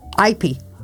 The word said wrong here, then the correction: Ipe: No, Ipe. – Ipe? That's how you say it.